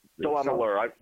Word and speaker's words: DeLamielleure: Joe DeLamielleure, as I mentioned earlier, was inducted to the DeLamielleure. – DeLamielleure. – It rhymes with Cavalier.